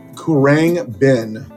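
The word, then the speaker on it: Khruangbin: Kurang Bin.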